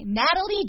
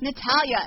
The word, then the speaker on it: Natalia: Well, it's funny because the lady on the thing was, like, saying, Natalie Dyer. I was like, Natalia.